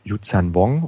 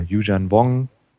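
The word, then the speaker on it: Yuuzhan Vong: Dann war die große Änderung mit den äh, ja, Yuzhan Wong oder Yuzhan Wong, da mhm. wieder, wie es einem beliebt, wie man es aussprechen möchte,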